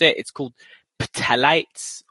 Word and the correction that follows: phthalates: or ptyalates? – Phthalates. – Yeah, phthalates. Yeah.